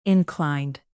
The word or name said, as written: inclined